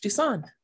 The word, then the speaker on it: Duson: It is Monique Duson.